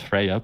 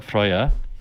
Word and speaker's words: Freya: And amongst the Vanir, there is uh, Freya, often pronounced Freya, but Freya.